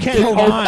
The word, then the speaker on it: Hovind: It's Kent Hovind. – Oh, sorry. Kent Hovind. Like, yeah. – It rhymes with bovine.